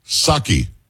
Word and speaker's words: Psaki: Ed, do me a favor, repeat after me. Psaki. It's not Psaki. It was just Psaki. You didn't pronounce the P.